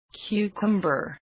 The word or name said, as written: cucumber